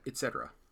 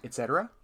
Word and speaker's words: et cetera: So many people say et cetera. – Et cetera, et cetera, et cetera. Okay. – With a, like a k.